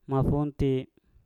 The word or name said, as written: mafunti